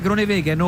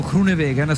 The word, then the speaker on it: Groenewegen: Nel frattempo il gruppo viene sempre condotto dagli uomini della Unibet Ross Rockets, che è la squadra di Dylan Groenewegen, o Groenewegen se preferite la pronuncia olandese,